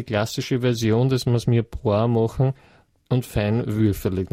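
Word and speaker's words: mirepoix: Mir Poir oder wie? – Mir Poir. – Mir Poir. Mhm. Was ist das? – Feinwürfelig. – Mir, wie schreibt man das? – Das ist Französisch.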